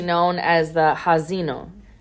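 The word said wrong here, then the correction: Ha'azinu: Ha-azino. I'm sorry, I said that wrong.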